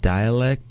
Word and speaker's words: Dalek: So Dalek, I think it's pronounced dialect, but it's D-A-L-A-K. D-A-L-E-K.